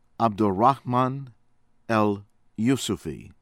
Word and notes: Abderrahmane El Youssoufi: American English

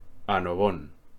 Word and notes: Annobón: Spanish

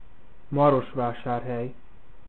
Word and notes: Marosvásárhely: maghiară

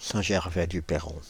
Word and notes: Saint-Gervais-du-Perron: Paris

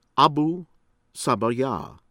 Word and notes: Abu Sabaya: American English

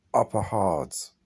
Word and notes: Upper Hardres: English

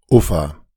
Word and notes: Ufa: German; [ˈuːfa]

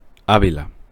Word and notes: Ávila: Spanish

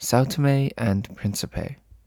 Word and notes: São Tomé and Príncipe: English

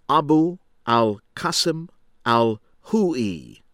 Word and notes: Abu Al-Qassim Al-Khoei: American English; from Iraq

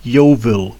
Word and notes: Yeovil: [ˈjoʊvəl]; English

